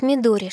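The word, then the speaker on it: Мидори (緑): Первый иероглиф в имени Сапфир читается как Мидори, что означает «зеленый».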